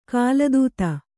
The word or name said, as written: ಕಾಲದೂತ